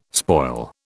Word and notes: spoil: British English

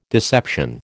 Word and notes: deception: American English

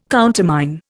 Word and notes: countermine: Indian

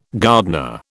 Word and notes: gardener: British English

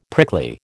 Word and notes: prickly: American English

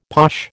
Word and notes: posh: American English

